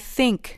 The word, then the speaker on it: think: I think. Think. I think. – به تلفظ دقت کنید. باید نک زبونی بگید. علاوه بر اون بعد از صدای تلفظش ای نیست. یه صدای بین ای و ای.